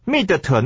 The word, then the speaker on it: Schmidt: 我们今天要首先来看的 A 段呢，是一个单字，就是第一段话里面呢，Cathy 啊所说的，她的那个 Good morning, Mrs. Schmidt。 Schmidt 这个字啊是很奇怪的拼法，各位看 S C H M I D T 这个是德文，对不对？ – 嗯哼。 – 所以呢，这个字念法各位注意啊，S C H 呢就念作 S H 的声音，那个 C 就不要理它，念作 sh，对不对？ Schmidt 那个 D 呢啊，在英文里面那个 D 不发音，我们叫 Schmidt，就是啊史密特这样的一个翻译的，是不是啊 Schmidt 哈、啊？